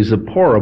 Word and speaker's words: Zipporah: I'd like to say Zipporah, but my Bible's got an accent mark on the first syllable, so I'm trying to revise my thinking to say it that way, but I don't know for sure if anybody really knows how those names were pronounced in those days.